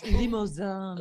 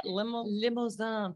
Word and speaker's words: Limousin: It's limousin. – Ooh. Ooh, what word is that? – Limousin. Think like limousine, but you say it like you're really French.